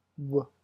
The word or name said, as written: w